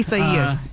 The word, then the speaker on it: Isaias: Tell us Isaias. A little bit more. Isaias. – Yes, which is not intuitive at all.